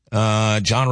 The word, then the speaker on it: Jon: John Roberts fan club. Remember, my name is spelled J-O-N, though.